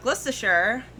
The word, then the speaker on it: Gloucestershire: 1965 in Gloucestershire, UK.